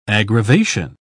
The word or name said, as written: aggravation